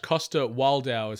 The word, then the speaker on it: Coster-Waldau: Nicolaj Coster-Waldau is how you pronounce his surname.